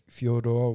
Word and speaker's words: Fyodor: You can bring in Fedor, if you want to say Fyodor, what the real pronunciation for his name.